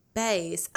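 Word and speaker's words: Baes: It's B-A-E-S. – Bays. Oh, yeah. Okay, so Bay is B-A-E, and it means before anyone else.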